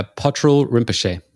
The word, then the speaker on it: Patrul Rinpoche: Yeah, Patrul Rinpoche.